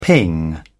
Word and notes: ping: British English

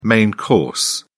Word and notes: main course: British English

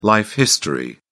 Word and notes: life history: British English

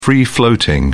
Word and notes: free floating: British English

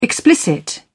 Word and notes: explicit: British English

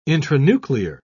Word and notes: intranuclear: British English

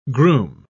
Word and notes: grume: British English

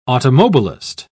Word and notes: automobilist: British English

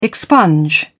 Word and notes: expunge: British English

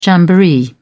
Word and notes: jamboree: British English

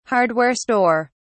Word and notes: hardware store: British English

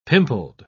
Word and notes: pimpled: British English

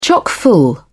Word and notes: chock full: British English

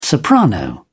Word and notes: soprano: British English

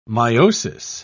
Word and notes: miosis: British English

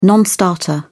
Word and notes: non starter: British English

